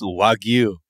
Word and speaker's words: Wagyu: You said Wagyu. It's Wagyu. Come on, man.